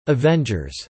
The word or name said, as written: Avengers